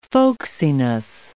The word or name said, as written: folksiness